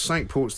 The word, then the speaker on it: Cinque Ports: Were they Norman or were they Well, there's Sandwich, and then there was Cinque Ports, then there was... – Can you name all five Cinque Ports, which is apparently how you say it? – Oh, right.